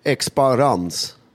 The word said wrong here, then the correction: Exparang: Exparang. – Exparang. – Tänk dig en boomerang på slutet och exp i början, som expose och expand och allt som är bra börjar på Exparang.